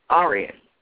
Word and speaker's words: Aryan: And Arian is spelled A-R-Y-A-N. Okay? Arian, English language loanword derived from the Sanskrit Arya.